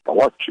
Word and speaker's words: Palocci: Olha, o, o, a prisão preventiva do Palocci, que, que, ele pronuncia certo, Palocci, tá né?